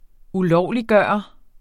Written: ulovliggøre

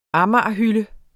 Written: amagerhylde

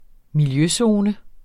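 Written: miljøzoner

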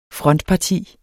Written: frontparti

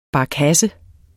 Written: barkasse

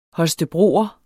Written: holstebroer

